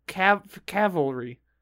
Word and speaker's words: cavalry: It says don't say Calvary. Do say cavalry. So cavalry. – I guess I do say that wrong. I put that extra L in the front there.